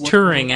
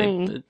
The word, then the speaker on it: Turing: Is it Turing? – Right. It's Turing, actually. Turing. But okay, go, Max.